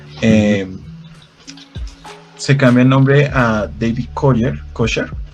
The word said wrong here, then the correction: Koresh: Permítame lo mm. reviso si lo estoy pronunciando bien, porque es importante. Koresh. David Koresh.